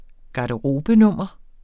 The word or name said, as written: garderobenummer